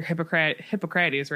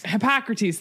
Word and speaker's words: Hippocrates: Hippocrates, right? – Hippocrates, thank you.